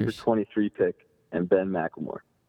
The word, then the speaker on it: McLemore: McLemore.